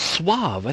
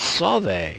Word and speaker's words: Sauve: I don't believe it's Suave. I think it's Sauve. It's spelled S-A-U-V-E apostrophe.